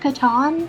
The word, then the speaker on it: Catan: Um, street food, festivals, Catan, CrossFit cosplay. I think I, I think it's pronounced Catan. Catan.